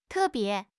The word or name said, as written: te4 bie2